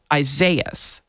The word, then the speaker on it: Isaias: Somebody posted that it actually is a form of Isaiah. – Yes, it is. And I actually have a friend named this name of this hurricane, but he pronounces it Isaias.